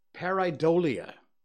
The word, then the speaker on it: pareidolia: Pareidolia.